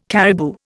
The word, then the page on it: caribou: Indian